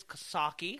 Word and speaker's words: Coxsackie: I know I don't say that right. It's Coxsackie.